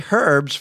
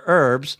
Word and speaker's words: herbs: And it pointed out to me that you can't actually say herbs for herbs in, I don't know if they say that in the UK, but the dictionary at least said that was an appropriate pronunciation.